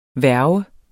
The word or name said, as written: værge